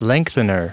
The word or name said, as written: lengthener